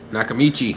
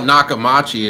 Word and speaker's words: Nakamichi: How do you say it? – Nakamichi. – I always mispronounce it. – I used to call it Nakamachi, and then somebody corrected me.